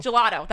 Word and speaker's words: gelato: And spicy Dijon gelato. Am I saying that right? – Gelato? – Gelato.